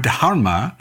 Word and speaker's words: dharma: So you write, when I use the word dharma, oh, I didn't say that properly. So I spent a week with a Sanskrit scholar who did her best to get me to pronounce the H in dharma. So I'm gonna say it again.